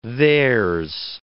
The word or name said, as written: theirs